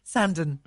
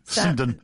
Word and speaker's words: Sandown: And then Wednesday the 23rd of September is at Sandown Park, is that right? – No. It's pronounced Fred. – Sandon. – Yeah. Sandon. – It's probably Sandon.